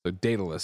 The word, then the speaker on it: Daedalus: At this point, Google's pronunciation of Daedalus is, Daedalus. So then it would be Daedalic.